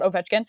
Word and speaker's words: Ovechkin: But if you're a player like Crosby or Ovechkin – why did I just say his name like that? Ovechkin.